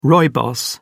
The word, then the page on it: rooibos: British English